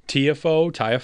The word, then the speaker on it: Tiafoe: Is it Tiafoe? – Tiafoe.